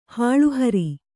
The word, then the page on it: ಹುಲ್ಲುಹೊರೆ: hullu hore